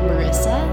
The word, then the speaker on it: Myrissa: And on Facebook, it's the same, but my name Marissa, and that's spelled with a Y, so MYRISSA.